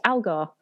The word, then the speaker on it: Algar: And I'm really happy today to be talking to my guest, Algar.